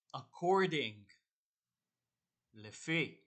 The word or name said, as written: according